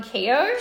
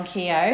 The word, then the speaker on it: Keogh: Sharon Keogh. – Sharon Keogh.